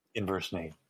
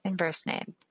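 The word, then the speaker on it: Inversnaid: Inversnade. Yeah. – Inversnade.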